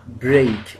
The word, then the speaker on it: break: Like B-R-E-A-K on its own is pronounced break. That's the correct pronunciation of break. B-R-E-A-K. It's break.